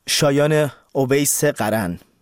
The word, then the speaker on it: شایان اویس قرن: شاید تلفظش رو هم اشتباه خوندید، شاید درست خوندید، شاید حد زدید. باید چگونه بخونید؟ شایان اویس قرن، دانشمند جوانی که 150 ساله در علوم رایانه رو شکسته و مدال آباکوس سال 2026 اتحادیه بین المللی ریاضیات رو دریافت کرده.